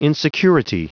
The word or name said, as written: insecurity